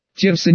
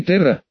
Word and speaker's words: Терса: Терса, не Терра, именно Терса, но если произносить с привычной вам землянам артикуляцией, то можно произнести как Терс, последнее гласное пропадает.